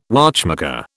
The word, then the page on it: watchmaker: British English